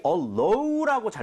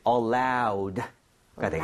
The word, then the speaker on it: allowed: 그런데 주의해야 될 게 많은 분들이 allow라고 잘못 발음하시는 경우 많아요. – 네. – 얘는 allowed가 되겠습니다. 알아도 연음해서 틀릴 수 있으니까 주의하셔야 돼요. allowed.